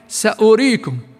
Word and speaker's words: سأريكم: ومثل الواو في كلمة سأريكم، فإن الواو لا تنطق وإن كانت مكتوبة، هي كيف مكتوبة؟ سأريكم، كأنها الواو لا تنطق وإن كانت مكتوبة، فاحذر أن تقرأها مع واو فتصبح وكأنك تمدها، بل قراءتها كيف؟ سأريكم، مكتوبة الواو ما بنلفظها، سأريكم.